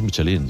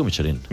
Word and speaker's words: Michelin: A ver, aquí en la Península Ibérica hacemos Michelin. – Sí, claro, pero la realidad, tú eres un chef internacional. – Ya, pero ¿tú cómo lo pronuncias? – Me cuesta ser tan tontorraco, ¿no? Dice Michelin. – ¿Michelin? – Yo digo Michelin.